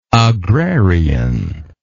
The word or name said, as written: agrarianly